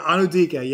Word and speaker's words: Anudike: Think about I always mispronounce it. Wazirike. Is that right? Wazirike. Fair enough. Yeah. – Anudike. – Whatever. Yeah, Anudike.